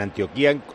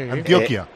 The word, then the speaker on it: Antioquia: En Antioquía, es Colombia, ¿no? Antioquía es Colombia. – Sí, sí, sí. – Antioquia. – Antioquia. – Antioquia. O sea, no tiene el acento en la I.